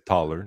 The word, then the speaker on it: Thaler: C'est Taylor, mais c'est Taller, dans le fond. J'ai mal prononcé. – Ouais, ben, j'avais compris qu'on parlait du gars de l'économie behavioriale. T-H-A-L-E-R.